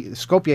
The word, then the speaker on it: Skopje: We had to take a bus to Skopje in Macedonia. – Skopje. – Here's the other thing. Okay, Skopje is how they say it, right? – Skopje.